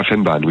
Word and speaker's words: Fennbahn: Et alors, il y a une ancienne ligne, on parlait de la ligne du Van Bamme, c'est ça? – De la Fennbahn, oui, la tout Fennbahn, à fait. La Fennbahn, pardon, ça prononce comme ça, ok.